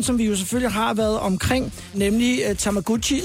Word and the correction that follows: Tamagotchi: som jo i virkeligheden udtales Tamagotchi.